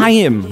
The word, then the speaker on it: Haim: Haim. That's how it's said, you fucks.